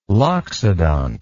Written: loxodont

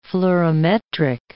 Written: fluorimetric

